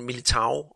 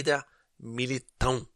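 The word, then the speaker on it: Éder Militão: fordi hvis man bare skal udtale, som står, så hedder han Militao, og det hedder han faktisk ikke, han hedder Eda Militao.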